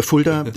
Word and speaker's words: Fulda: Oh, das ist ja hier im, im äh, erzkatholischen Kulda, äh, Fulda, ähm, äh, ich kann es nicht mehr, mehr richtig aussprechen.